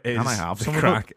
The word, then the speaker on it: craic: See, but of course, it's spelled C-R-A-I-C and not C-R-A-C-K. Not like, can I have some crack? – Yeah, what's the crack?